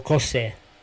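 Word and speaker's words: José: Cosè si scrive come Juventus, no? La esatto. – J? Mi hanno detto, devi leggerlo Cosè.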